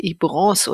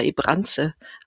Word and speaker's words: Ibrance: Das erste Medikament vom Inhaltsstoff her war das Palbociclib, der Handelsname Ibrance oder Ibranze ausgesprochen.